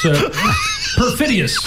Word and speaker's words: perfidious: Let me correct you, my mispronouncing friend. You know, it's perfidious.